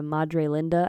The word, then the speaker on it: Madre Linda: They're living in, was it Madre Linde? Did I say that right? – Yeah, Madre Linda, I think. – Linda, yeah, that's right.